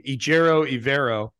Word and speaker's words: Ejiro Evero: Uh, I learned today that's how he pronounces it because I watched a video where he pronounced his own name, Ejiro Evero, um, the DC for the Denver Broncos.